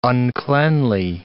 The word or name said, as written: uncleanly